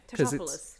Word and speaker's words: Tatopoulos: Tatopoulos.